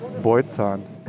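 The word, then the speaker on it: Bojcan: Nee, ich bin, äh, siehst du, der Name falsch ausgesprochen. – Verdammt, also, ich habe extra geübt. – Also ich bin der äh, Mann, der sich vor 25 Jahren den Künstlernamen Kurt Krömer gegeben hat, äh, weil keiner den Nachnamen aussprechen kann. – Und dann, ich dachte, dann da muss bitte einmal. – Beutzahn. – Okay, es tut mir sehr leid.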